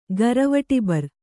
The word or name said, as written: ಗರವಟಿಬರ್